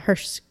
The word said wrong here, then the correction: hearse: You're talking about the car? – Yeah, how do you say it? – Hearse. – Okay, that one.